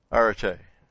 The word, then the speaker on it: Arete: And it's Arete. Yeah, it's just A-R-E-T-E. Long E, actually. On the end there.